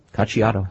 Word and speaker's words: Cacciato: Well, let's talk about going after Cacciato. Am I pronouncing that correctly? – You are. Cacciato.